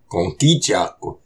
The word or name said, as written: Kôkicaq